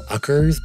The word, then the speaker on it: Uckers: Yeah, it's called Uckers by Shy Girl, as in, I guess, fuckers without the F.